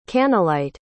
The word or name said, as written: kannelite